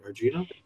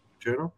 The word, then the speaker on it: Argento: Uh, I'm going to say it right, Argento. Argento.